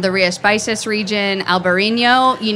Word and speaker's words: Rías Baixas: Rías Baixas, it's spelled R I A S B A I X A S.